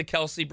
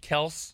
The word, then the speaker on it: Kelce: This is like the Kelce brothers. All right, you're supposed to pronounce it Kels.